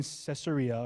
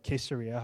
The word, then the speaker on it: Caesarea: And when he landed in Caesarea, or Caesarea, however you'd like to pronounce it, he went up and greeted the church.